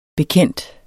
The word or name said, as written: bekendt